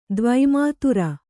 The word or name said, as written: ದ್ವಿಮಾತ್ರ